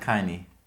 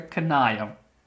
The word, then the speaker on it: Kanye: Ich sag Kanye. Okay, du sagst Kanye und ich sage Kanaya. Kanayo habe ich noch nie gehört.